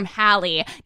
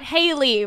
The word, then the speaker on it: Hallie: Okay, this one is from Hallie. Not Haley, rhymes with Sally.